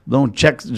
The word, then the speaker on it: John Textor: John, Textor.